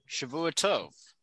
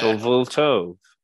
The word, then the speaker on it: Shavua Tov: Shavua Tov. We uh, Shovel toe.